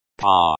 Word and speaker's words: По: It is pronounced like па.